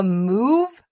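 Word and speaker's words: Muve: So it's like a robotic vacuum, and it's called a Move. I think I'm pronouncing this right, but it's M, then UVE, so with the UV capitalized.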